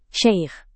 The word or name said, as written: syaikh